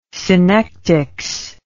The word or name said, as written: synectics